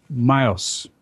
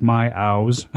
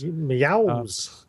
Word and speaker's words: Myows: My first one, I'm only mentioning it because I thought it had some very interesting capabilities, was Myos, is how you say it, although it looks like my owls. My, okay. So M-Y-O-W-S. – Meows.